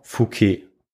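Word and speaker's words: Fouqué: Und sein Name war Ferdinand Fouquet, also ein Franzose, so wie ich es schon ausgesprochen habe.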